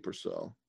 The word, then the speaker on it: Purcell: Hold on, I got it. Purcell? – Dominique Purcell. – Purcell. – Yeah. – Okay. – Dominique Purcell. – Right. Yeah, Dominique Purcell.